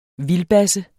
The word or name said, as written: vildbasse